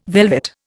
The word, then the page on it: velvet: Indian